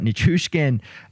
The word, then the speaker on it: Nichushkin: I just pronounced his name two different ways, but Nichushkin,